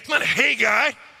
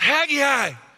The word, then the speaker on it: Haggai: It's not Haggai, it's Haggai.